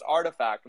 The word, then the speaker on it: RTFKT: If you've seen these letters around, RTFKT, I've seen it pronounced every which way. I'm sure these guys can attest to. But from what I know, it's pronounced Artifact, right, guys? – Yep, that's correct. Right.